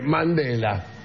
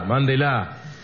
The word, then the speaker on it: mándela: Dice, Mandela. – Pero eso es un documental. – No, con acento en la A. – Claro, porque mi marido me decía. – Con acento en la A, mándela.